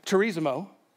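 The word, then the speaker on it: Charizomai (χαρίζομαι): Anyone know how to pronounce that? I heard several people say it the wrong way this week. So we'll say it the wrong way right now. Charizomai, of course, is how you say that.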